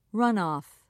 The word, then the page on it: runoff: British English